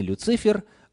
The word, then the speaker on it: Люцифер: Кстати, согласно орфографическому словарю, ударение нужно ставить именно на последний слог. Не «Люцифер», а «Люцифер».